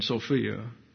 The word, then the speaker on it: Sophia: And I told Mike Best, I said, when sweet Sophia, that's the Greek pronunciation, Sophia.